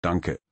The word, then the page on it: Danke: German